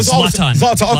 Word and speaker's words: Zlatan: How you pronounce the guy's name? I was messing his name up. Ibrahim Rimovic. Help me out. Zoltan. – Yeah, I should call him Zoltan. – Zlatan. Okay, Zlatan. Even the beef between, you can tell those two guys do not like each other.